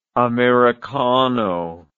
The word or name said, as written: americano